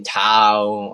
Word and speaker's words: Tao: It kind of rhymes my last name, Tao, or at least how you pronounce it in English.